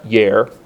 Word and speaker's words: yare: The word fear in Hebrew is the word yare, which is Y-A-R-E. Looks like yare, but you would pronounce it yare.